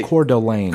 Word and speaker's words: Coeur d'Alene: Coeur d'Alene. – Coeur d'Alene. – Coeur d'Alene. – Coeur d'Alene.